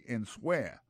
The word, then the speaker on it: answer: A-N-S-W-E-R. Alors, souta, pour un temps pour prononcer, il y a tout mon clan, on tape dit answer. C'est pas comme ça, on dit c'est answer. C'est quand que W à lui-même, il vient rester bébé.